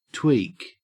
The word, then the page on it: tweak: Australian English